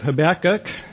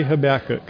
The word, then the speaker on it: Habakkuk: Welcome to the beginning of our study of the book of Habakkuk. The plan, I say Habakkuk, you may hear it pronounced differently. Habakkuk. Hook. I say Habakkuk.